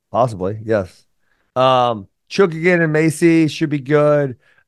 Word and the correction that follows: Chookagian: Bro, you are bad with name pronunciation. – Chookigan. – Caitlin Chookagian, bro. – Well, there's no way you can know that. – I swear to God, Caitlin Chookagian.